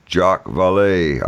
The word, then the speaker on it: Jacques Vallée: Writers such as John Keel, Jacques Vallée, I guess that's how you pronounce it. Am I right there? Did I get it right or no? – You got it right.